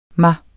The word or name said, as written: mig